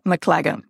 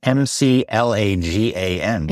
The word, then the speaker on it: McLagan: so I'm here with Jennifer McGla- McLa- McLaughlin. Is that right? Absolutely not. – Oh, no. Jennifer McLagan. McLagan. Yes. Okay, but it's spelled, we're going to spend an hour talking about your name. Yes. It's M C L A G A N. – Yeah, McLagan.